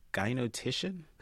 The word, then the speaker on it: gynotician: Is gynotician? Am I pronouncing that right? – Yes. Okay, so that takes the gyno prefix and attaches it to titian, as in politician.